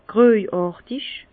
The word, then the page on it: gröjàrtig: Bas Rhin; Herrlisheim